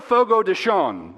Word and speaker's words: Fogo de Chão: Listen, when I go to Fogo de Chão, that's how it's pronounced.